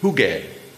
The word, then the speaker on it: Hygge: Hygge, right? – What is that? – Hi. Apparently it's not meant to rhyme with piggy.